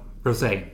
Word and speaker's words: Rosé: Men min bästa måste jag säga till Rosie. – Ja, Rosé. – Rosé, alltså Rosé. Det här med att uttala rätt. – Tänk bara på den goda rosa drycken som man dricker.